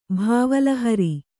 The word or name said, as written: ಭಾವಲಹರಿ